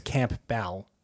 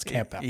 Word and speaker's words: Campbell: Michael A-E-L or E-A-L always gets wrong. Right, yeah. – And Campbell, even though it's Campbell. – Yeah. – People, I think that's too easy. – Yeah. – And they expect it's like missing a letter, but it's not. It's actually as simple as it sounds, Campbell.